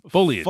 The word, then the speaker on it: foliage: Foliage. – You foliage. – Said it right. Foliage. You said it right. Foliage. – I know. But I'm saying foliage, like Three Stooges.